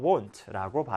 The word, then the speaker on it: won't: 발음에 유의를 해주시고요. won't라고 발음을 합니다.